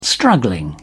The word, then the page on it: struggling: British English